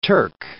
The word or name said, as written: Turk